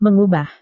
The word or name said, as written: mengubah